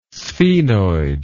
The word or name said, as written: sphenoid